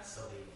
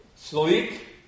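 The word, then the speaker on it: Salique: And this word is, it's spelled S-A-L-I-Q-U-E. I don't know how to pronounce that. Perhaps you do. How would you pronounce that? – Salik. – Salik. That's one pronunciation.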